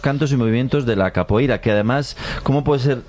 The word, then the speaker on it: capoeira: Pues todos sabemos lo que es la copeira, ¿verdad? – Capoeira. – Gemala. Qué mal pronuncio últimamente.